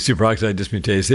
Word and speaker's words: superoxide dismutase: It's called superoxide dismutase. Am I pronouncing that right? – Oh, yeah. Yeah. SOD, superoxide dismutase.